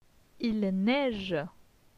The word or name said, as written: Il neige